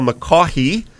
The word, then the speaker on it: McCaughey: And we're also going to be speaking with Sister Mary Paul McCaughey, the G is silent, there's just G somewhere in that name, who is our superintendent of Catholic schools.